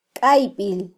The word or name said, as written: kaybil